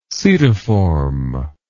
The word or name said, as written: setiform